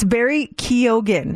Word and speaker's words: Barry Keoghan: I just call him Barry Keoghan, and it's Barry Keoghan.